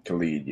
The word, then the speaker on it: Khalid: Khalid, yeah.